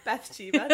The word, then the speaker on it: Bathsheba: I was going to say, back to the meta psychics, they said that, what's her name? Bathshilda? Why can't I say it? The witch? – Bathsheba? – Bathsheba, thank you.